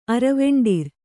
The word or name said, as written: ಅರವೆಂಡಿರ್